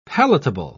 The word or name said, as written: palatable